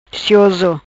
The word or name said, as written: Cioso